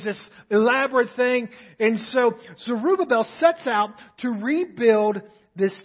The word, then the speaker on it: Zerubbabel: Joshua is the head of the priests and Zerubbabel. Roy taught me how to pronounce that, by the way. Zerubbabel, he was the governor.